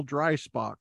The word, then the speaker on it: Dreisbach: Today we have wonderful guest, Daniel Dreisbach.